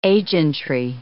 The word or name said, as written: agentry